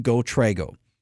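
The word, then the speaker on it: GoTrego: GoTrago. Trago is spelled T-R-E-G-O. It's GoTrago.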